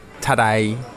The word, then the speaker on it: Tadej: And I was explaining to some of the members of the staff at UAE Emirates, Pogacar's team yesterday, that his first name, Tadej, is basically pronounced like today in a Cockney accent. Tadej.